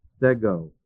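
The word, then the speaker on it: Stego (στέγω): The Greek word S-T-E-G, long O. Stego.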